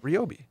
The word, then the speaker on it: Ryobi: Ryobi.